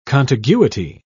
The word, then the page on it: contiguity: British English